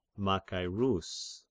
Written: Machairus

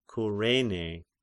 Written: Kyrene

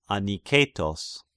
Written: Aniketos

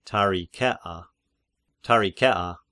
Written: Tarichea

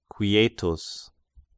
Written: Quietus

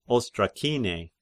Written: Ostrakine

